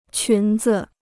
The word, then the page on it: 裙子: qún zi